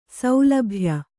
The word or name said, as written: ಸೌಲಭ್ಯ